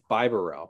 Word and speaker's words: Bibarel: I think it's Bibrowly. I don't know. – I think it's Bibarel. – Bibarel. Okay. So we need to talk about Bibarel.